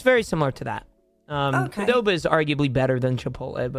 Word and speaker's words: Qdoba: Qdoba.